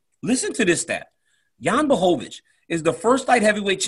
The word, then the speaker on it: Błachowicz: You got to say it right. Bois. Jan. It's time to start pronouncing it the correct way. – Yeah. Błachowicz.